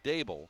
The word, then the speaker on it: Daboll: Like he said it's pronounced Daboll like table.